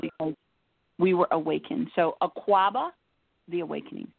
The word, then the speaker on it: Akwaaba: Akwaba, The Awakening. And Akwaba is spelled A-K-W-A-A-B-A, and it's The Awakening.